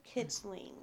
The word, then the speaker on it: kitling: What's a kilting? Is it a horse or what? What is it? – Kitling. – Kitling. Okay, so a kitten. – Yes, a kitling.